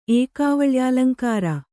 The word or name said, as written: ಏಕಾವಳ್ಯಾಲಂಕಾರ